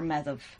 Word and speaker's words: Maeve: Yeah, Maeve, by the way, in Old Irish, we'd pronounce her Medhav. So, okay. – But Modern Irish is Maeve.